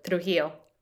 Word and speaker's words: Trujillo: Pronounces it properly. So Raul Trujillo, that's what I'm saying. And how did you pronounce? You were like Raul Trujillo. – Ah, oh, so much better. – By virtue of speaking Spanish.